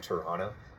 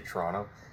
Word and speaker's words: Toronto: I tried to say it like Toronto because that's how everybody else says it. But if you're from Toronto, you just say Toronto. You can always tell when somebody's, you know, from there or not.